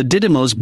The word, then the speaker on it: Didymos: And the mission involves NASA launching a spacecraft that will rocket towards the Didymos, D-I-D-Y-M-O-S, binary asteroid and collide with it.